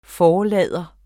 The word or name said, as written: forlader